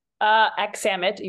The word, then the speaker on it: Aksamit: Aksamit, you were close.